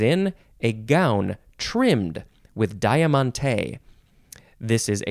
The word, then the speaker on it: diamante: In Italian, it's pronounced diamante. The accent's over the mon instead of the te.